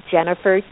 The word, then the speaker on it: Jennefer: It's at Jennifer TBG. And my first name is spelled funny. It's J-E-N-N-E-F-E-R.